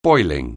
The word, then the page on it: boiling: anglais